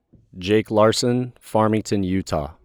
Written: Jake Larson

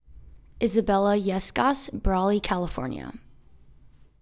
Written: Isabella Yescas

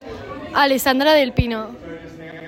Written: Alessandra Del Pino

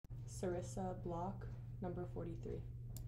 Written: Sarissa Block

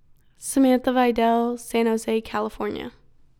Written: Samantha Vidal

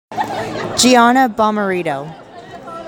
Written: Gianna Bomarito

